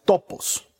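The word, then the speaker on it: topos (τόπος): Ahora, esta palabra, esta frase da lugar, viene de una palabra griega que se pronuncia topos y que literalmente significa darle espacio darle cabida, o más literalmente todavía, darle una habitación.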